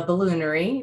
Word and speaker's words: Balloonairy: And you did you did say that correctly. It's the balloonairy. It's a little play on distillery, kind of.